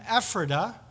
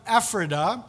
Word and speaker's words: Ephrata: Then in Micah 5, you know, there's that famous prophecy we think about this time of year, because it says, in Bethlehem Ephrata, good Pennsylvania German pronunciation of that verse, in Bethlehem Ephrata, that the Messiah is going to be born there.